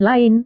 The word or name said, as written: lain